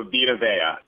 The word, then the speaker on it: Vita Vea: Oh, Vita Vea.